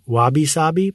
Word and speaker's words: wabi-sabi: It's wabi-sabi. Is that right? – I believe the Japanese might say wabi-sabi, but yes, go ahead.